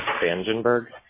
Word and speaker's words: Spangenberg: Vangenberg. – Vangenberg. Can you spell that for me, please? – S-P-A-N-G-E-N-B-E-R-G.